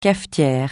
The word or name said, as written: cafetière